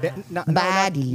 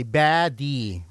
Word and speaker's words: Baddie: Since then, she has been dishing out songs and albums, including her latest single, how do you pronounce it? Buddy, right? Baddie. – Not our beautiful body. Baddie.